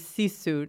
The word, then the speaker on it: sisu: Yeah, I, I noticed on your website, and uh, your bio, it, it says use sisu, not sugar.